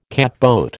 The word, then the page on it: catboat: American English